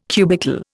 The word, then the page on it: cubical: Indian